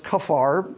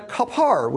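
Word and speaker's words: kaphar: I've heard it pronounced kafar because PH in English is an F sound, but he goes to the kapar, which is the covering,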